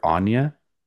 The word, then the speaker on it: Anya: I've always called her Anya, but Eggers was calling her Anya in the movie or in the commentary. So guess I've been saying that wrong.